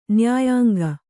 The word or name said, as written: ನ್ಯಾಯಾಂಗ